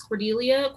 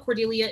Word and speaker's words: Cordelia: That's spelled C-A-N-D-A-C-E 16. And you can also find me on Twitter at Candace Cordelia. Cordelia is C-O-R-D-E-L-I-A.